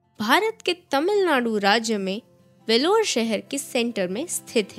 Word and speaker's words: Vellore: वेलोर not बेलोर not वेलोरी it's वेलोर